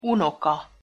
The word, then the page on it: unoka: Hungarian